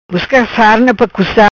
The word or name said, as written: Busca Sarna